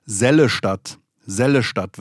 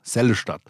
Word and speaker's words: Seligenstadt: Seligenstadt, das gab auch Shitstorm. Sellestadt wird es ausgesprochen. Sellestadt.